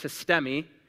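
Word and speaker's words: histemi (ἵστημι): It's pronounced histemi.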